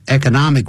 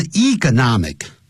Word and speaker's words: economic: Economic recession 也可以念成 economic 也可以念成 economic 两个念法都可以